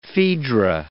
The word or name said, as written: Phaedra